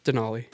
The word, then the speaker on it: Denali: It's Denali. – Denali. – Yeah, that's okay. Some like mass roots coming through. – Yeah, the mass. – There's no Duncan D's up there. – Fucking Denali guy.